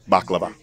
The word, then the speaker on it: baklava: Baklava.